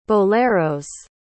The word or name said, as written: boleros